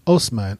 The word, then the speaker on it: Osman: These horse warriors, they were originally pagan, but over time, their descendants converted to Islam, and one of their descendants was a warlord named Osman, O-S-M-A-N, which would have probably been the Turkish pronunciation of Othman.